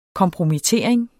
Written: kompromittering

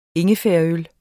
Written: ingefærøl

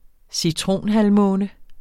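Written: CITRONHALVMÅNE

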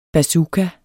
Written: bazooka

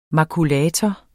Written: makulator

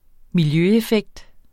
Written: miljøeffekt

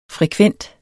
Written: FREKVENTE

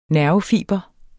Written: nervefiber

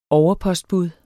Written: overpostbud

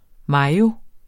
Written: mayo

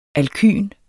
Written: alkyn

